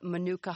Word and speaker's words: manuka: I had to look up what manuka honey does, and I'm not even sure I'm pronouncing it correctly.